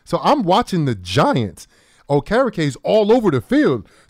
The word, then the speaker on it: Okereke: He made tackles, all over the field, you know, to the point where I learned how to pronounce his name, Okereke. And I was calling him O Carrot Cake. That's how I remember how to say his name, you know.